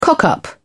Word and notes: cock up: British English

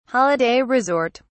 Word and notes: holiday resort: British English

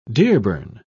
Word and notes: dearborn: British English

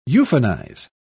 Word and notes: euphonize: British English

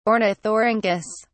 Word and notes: ornithorhynchus: British English

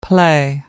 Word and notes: play: British English

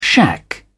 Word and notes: shack: British English

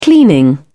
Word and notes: cleaning: British English

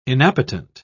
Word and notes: inappetent: British English

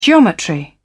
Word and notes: geometry: British English

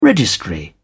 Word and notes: registry: British English

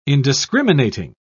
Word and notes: indiscriminating: British English

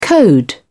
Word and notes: code: British English

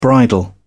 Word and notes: bridle: British English

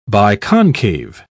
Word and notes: biconcave: British English

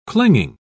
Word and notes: clinging: British English

